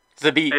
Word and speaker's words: Zabit: I feel like I'm about to say his name 100% wrong, but his name is Zabit. – Zabit. – It's 100%. Yeah, Zabit. There you go.